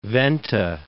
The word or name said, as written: Venta